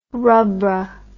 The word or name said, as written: Rubbra